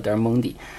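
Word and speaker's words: Del Monte: 呃，我们都知道他在一五九四年啊，我呃就是去了这个德尔蒙特啊，我们上次也叫德尔蒙蒂啊，就是好像意大利语发音叫德尔蒙蒂。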